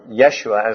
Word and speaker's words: Yeshua: Joshua, which is the original, or Yeshua as the Jews would have pronounced it, comes from two words, Jehovah or Yeshua, which is of course the name for God.